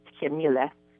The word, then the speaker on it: Qamile: It's really difficult to pronounce, but in English, it would be Camille. – In English, Camille. Okay. – Yeah, it's Camille. Q-A-M-I-L-E.